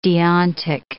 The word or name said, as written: deontic